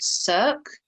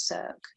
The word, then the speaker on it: Circe: band, well, it's not a band, it's a solo artist called Cirque, I think how you say it. So it's CIRCE. I think you'd say that's Cirque.